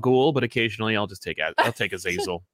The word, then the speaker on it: Azazel: Azazel. They say it in the movie Azazel, so I just pronounce it that way.